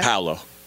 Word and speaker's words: Paolo Banchero: And I said, Paolo Banchero. Is that how you say it? – Paolo. – Paolo? – Paolo. – Paolo Banchero. – Banchero, yeah.